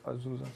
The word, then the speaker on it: Azusa: Äh, in, als, äh, als, als, wer kann es gut aussprechen? Azusa? Azusa? – Ja, okay.